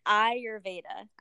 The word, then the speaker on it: Ayurveda: Yes, Ayurveda. – Ayurveda. – So it's like your I, your Veda.